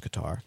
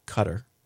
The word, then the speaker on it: Qatar: You'll notice that I said Qatar that time, because after promising for months to look up the proper pronunciation of that word, which I had always pronounced Qatar, but which this underwriting insisted was pronounced Cutter, I finally got around to doing it during the show last week, and I was right all along, it's Qatar.